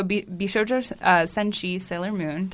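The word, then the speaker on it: Bishoujo Senshi Sailor Moon (美少女戦士セーラームーン): So Bishoujo Senshi Sailor Moon, did I say it right? – Yeah.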